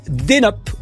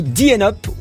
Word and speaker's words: DNUP: Alors ça s'appelle Denup ou Dienup ou Dnup. Chacun le prononce comme il veut, mais c'est vrai, hein, plus. Ça s'écrit D-N-U-P.